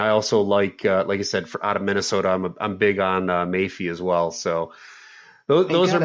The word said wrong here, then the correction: Boye Mafe: Boye Mafe. You got to say it with some bass in your voice.